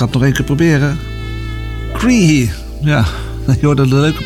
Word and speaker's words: Croithe: Schrijft C-R-O-I-T-H-E. En dat moet je dan uitspreken als Cree of zoiets. Het is Iers.